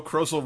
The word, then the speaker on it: Krozel: Saturday, November 14th by Joe Krozel, Rhymes with Puzzle.